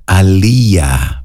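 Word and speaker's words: Aaliyah: Το όνομά της στα Σουαχίλι σημαίνει Highest Most Exalted One, και το όνομά της προφέρεται σωστά στα Σουαχίλι Αλία, Back and Forth.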